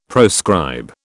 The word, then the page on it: proscribe: British English